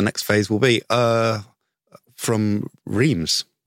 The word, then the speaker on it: Reims: I can definitely say, but this is Reims. It's easy. – So it's much easier to pronounce. – And Reims.